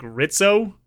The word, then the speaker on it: Rizzo: His name is spelled Rizzo, but I think they say it like Ritzo the whole time,